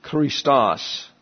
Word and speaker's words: Christos: Then we have the CH here, Christos, accent on the second syllable, right here.